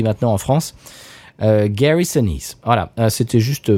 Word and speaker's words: Gary Sinise: Alors il, prononce Gary Sinise, bon, bien évidemment, si on n'entend pas prononcé par un Américain, on peut pas le deviner, mais c'est Gary Sinise. Voilà, ça se dit Sinise et non pas Sinise.